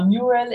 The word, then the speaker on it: Mural: Ja, also Mural, Mural geschrieben, wie man es jetzt genau ausspricht, da scheiden sich die Geister. Ich sage immer Mural in der englischen Aussprache.